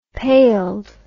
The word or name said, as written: paled